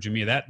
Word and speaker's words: Jumia: The Jumia. I just want to make sure I'm pronouncing that correctly. Yeah. Yeah.